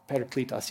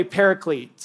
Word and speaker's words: Paracletos: Paracletos, yeah, that guy. Way to go. I've heard people say paraclete. So that's a terrible, it's not Greek at all.